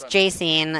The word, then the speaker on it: Jacine: So it's Jacine, and everyone's calling me like Justine or Jason or just so.